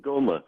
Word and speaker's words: ngoma: So it in the end, I mean, or where do you stand currently on what that in, how was it pronounced, Ngono, the drum? – Ngoma.